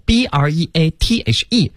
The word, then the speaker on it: breathe: 对，所以大家一定注意哈，有 e 的时候 breathe 才是动词，它没有 e 呢，它只能读成 breath，它是一个名词儿啊。